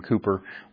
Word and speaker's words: Cowper: William Cooper looks like Cowper, but you pronounce it Cooper.